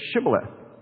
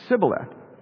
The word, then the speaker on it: Shibboleth: If he said no, then they would say to him, then say, "Shibboleth." And he would say, "Sibboleth." For he could not pronounce the H of Shibboleth.